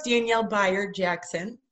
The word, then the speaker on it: Danielle Bayard Jackson: No, that works, Danielle Bayard Jackson.